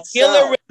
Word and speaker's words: Gila: How do you pronounce it? – No, that's south. – It's Gila River Arena, it's called.